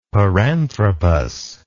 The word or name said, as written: paranthropus